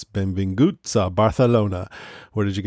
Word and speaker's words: Benvinguts: I went online and got a pronunciation. They said Benvinguts, like that. Benvinguts.